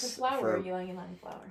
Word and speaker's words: Ylang Ylang: Jasmine and Lang Lang. I'm going to butcher that, but it smells so good. – Oh, Ylang Ylang.